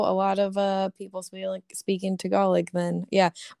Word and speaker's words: Tagalog: Dude, speaking of things that I never knew how to pronounce, my whole life I pronounced Tagalog like that instead of Tagalog.